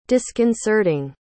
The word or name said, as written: disconcerting